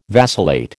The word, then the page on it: vacillate: American English